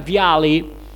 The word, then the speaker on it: Vialli: Ora la pronuncia del suo nome da parte degli inglesi è Gianluca Vialli, che fa rima con Charlie, che vuol dire cocaina.